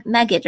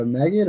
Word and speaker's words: Magit: Yeah, yeah, you mentioned uh, Magit or Magit or however it's pronounced. – I, I always get confused whenever I yeah. – say things. Yeah. So you want to say it's like it's it's like magic, but it's also git, right?